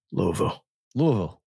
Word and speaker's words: Louisville: Louisville. With Southern, do a little Southern slang. – Is that how you have to say it? – Louisville. Mm-hmm. You mumble it.